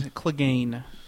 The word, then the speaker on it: Clegane: I can't pronounce Clegane. His name is Clegane.